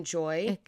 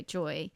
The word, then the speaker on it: Joie: So it's spelled J-O-I-E. Mm. So I can see why you're saying Joey, but I think when I've heard it, it's been Joy. – Okay, Joy. – Because J-O-Y would be Joy, and that could even, like, Joey, yes.